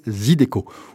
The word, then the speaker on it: Zydeco: le Zydeco. Ça s'écrit Z-Y-D-E-C-O, on peut le prononcer un peu comme on veut, à l'anglaise ou à la française, ça n'a pas d'importance.